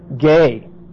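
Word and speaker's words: ge (γῆ): Ergos plus this other word, ge. See, ge, ergos. Ergos to work. G-E, long E.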